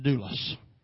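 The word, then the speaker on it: doulos (δοῦλος): That word servant in the Greek is pronounced doulos.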